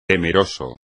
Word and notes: temeroso: espagnol

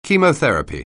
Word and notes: chemotherapy: English

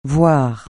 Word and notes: voire: francais

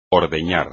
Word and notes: ordeñar: espagnol

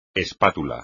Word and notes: espátula: espagnol

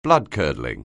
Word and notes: bloodcurdling: anglais

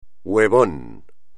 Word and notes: huevón: espagnol

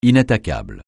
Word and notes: inattaquable: francais

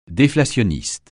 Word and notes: déflationniste: French

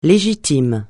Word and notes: légitime: francais

